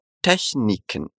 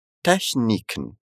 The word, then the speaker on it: Techniken: Essa variação do CH que nós tivemos na palavra anterior também acontece na próxima. Repete comigo como se fala técnicas em alemão. Techniken. Techniken.